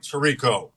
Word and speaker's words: Tirico: The great Mike Tirico.